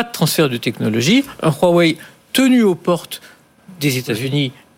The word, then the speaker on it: Huawei: Et, on le voit l'important, C'est important ce que vous dites, parce qu'on a on a vu Huawei, euh, alors Huawei, voilà pour bien le prononcer